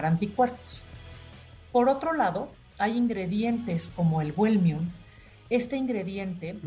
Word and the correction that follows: Wellmune: ¿Wellmium se llama? – Está con W y Wellmune se escribe.